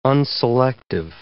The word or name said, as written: unselective